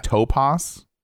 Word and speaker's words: Tupas: See, it's T-U-P-A-S, right? But you're pronouncing it Topaz? – It's Topaz. – That's weird. – Yeah, that's how they pronounce it on the show. – I get it. I'm just saying it looks like Tupas.